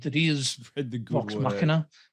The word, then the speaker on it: Machina: Machina!